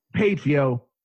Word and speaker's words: Patreon: Our Patron page. Yes, the Patron page. – Patreon. – Yeah, Patreon. I can never say it right.